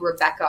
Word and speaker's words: Rebekah: I'm pretty active on LinkedIn, and my name is spelled Rebecca, R-E-B-E-K-A-H, Taylor.